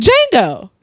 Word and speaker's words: Django: Django. – Nah, nigga, it's a D in that bitch. – He said in the movie, the D is silent.